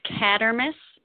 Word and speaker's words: Kadramas: It's Kadramas. – Kadramas. Okay. Just like it sounds. Just like it's spelled, right? – Yep.